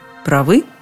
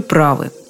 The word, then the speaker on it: правы: Но сказать «вы правы» – это точно неправильно. Если уж сдаешься, скажи, как полагается, «вы правы».